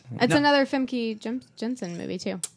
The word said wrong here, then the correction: Famke: Ooh, how do you say her name? – Famke.